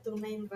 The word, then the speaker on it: TuneIn: Así se pronuncia, TuneIn Radio.